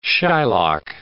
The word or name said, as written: shylock